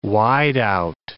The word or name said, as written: wideout